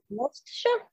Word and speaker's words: Worcestershire: I've got double Worcestershire. Is that how you pronounce that? – Yeah.